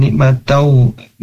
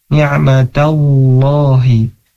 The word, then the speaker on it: ni'matallahi: Misalnya tadi di lafaz Allah, ta'uddu ni'matallahi, jangan ni'matau, ni'mat. Nah, tadi harus dibacanya ni'matallahi innallaha, terus saja sadaqallahul azim.